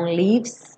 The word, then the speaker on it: leaves: Lembra que o E, o último E do leaves não é pronunciado. Então, leaves, do V você já vai direto para o S. E esse S já liga no R.